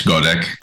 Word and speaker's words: Goddek: In English, Goddek.